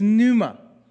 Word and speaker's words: pneuma: In the New Testament, written in Greek, the word for Holy Spirit is pneuma. P is silent. Pneuma.